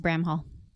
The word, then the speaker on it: Bramhall: Yes, it is Marliee, and it is Bramhall.